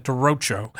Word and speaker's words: derecho: And the volunteers went to Cedar Rapids, Iowa in September after that derecho.